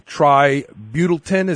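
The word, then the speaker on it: tributyltin: Okay, so let's talk about this particular chemical, tributin. Is that, am I pronouncing it right? – That's correct.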